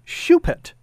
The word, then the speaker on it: Choupette: And it was spelled C-H-O-U-P-E-T-T-E, pronounced Shupet.